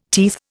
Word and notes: teeth: Indian

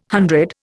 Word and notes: hundred: Indian

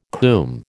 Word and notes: coomb: American English

